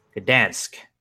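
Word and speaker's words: Gdańsk: Gdansk. Is, am I saying that right, Jeff? I am not Polish, and I don't have too many Polish friends, so I I'm gonna go ahead and say you're saying it correctly.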